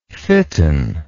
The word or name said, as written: fitten